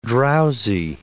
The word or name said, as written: drowsy